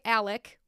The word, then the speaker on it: Alec: He goes by Alec, which is spelt like Alex, because it's a nickname from his middle name, Alexander.